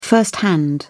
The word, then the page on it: firsthand: British English